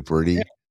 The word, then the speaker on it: Purdy: And I, if the 49ers don't win out, and that Purdy, is it, am I pronouncing it correctly, Purdy? – Yeah.